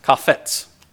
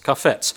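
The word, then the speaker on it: kafetz (חפץ): The word pleased at the very beginning of verse 10 that we started reading in the Hebrew is kafetz, kafetz. And it's spelled C-H-A-P-H-E-T-S, kafetz, which means to desire, to be pleased with, to will.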